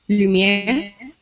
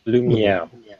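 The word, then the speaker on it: Lumière: how is his name pronounced again? – Lumiere. – Lumiere. – Lumiere. – Which actually, in French, actually means light.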